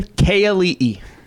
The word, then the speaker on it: Keeali'i: Keeali'i. – Keeali'i. Okay. – There you go. Keeali'i. Yeah. Everyone thinks it's K-E. It's K. So Keeali'i.